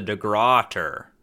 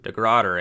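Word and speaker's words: Dei Gratia: Briggs delayed the final launching of the voyage due to uncertain weather conditions, and during that time, a Canadian ship, the De Gratia, was waiting nearby. – Oh, no, it's actually pronounced the De Grotter. – Mm-hmm. – De Grotter, eh? – Yeah.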